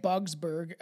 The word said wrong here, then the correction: Budsberg: It's Budsberg.